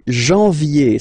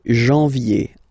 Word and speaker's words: janvier: No, wow, no, but anyway, janvier, so that's the way to pronounce it, janvier, A-N, en, okay, and then yé, yé, yé, janvier.